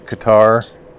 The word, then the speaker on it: Qatar: Qatar. Am I saying that right? Qatar. Qatar. Qatar.